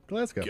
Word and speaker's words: Glasgow: Silent W, you chump. – No. – Glasgow. Go. – Okay, whatever. – Yeah, if you take the W out, it still says go.